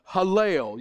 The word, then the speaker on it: Hallel: The Hebrew word for praise is spelled H-A-L-L-E-L. We Southerners have a distinct advantage on pronouncing this correctly, or as far as we know how the Hebrews would have said it. You got to get your Southern on and say Hallel, kind of like Randy and Judy Lael's last name in our congregation.